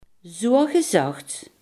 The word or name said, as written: zogezegd